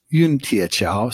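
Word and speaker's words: Yun Tie Qiao: Yun Tie Chao. So the spell that would be Y-U-N. – Oh yeah. Okay. – And then T-I-E.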